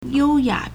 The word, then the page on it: 幽雅: yōuyǎ